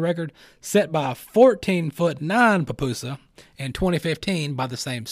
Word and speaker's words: pupusa: They've got a world record for an 18-foot pupusa. Make sure you say that right. Pupusa.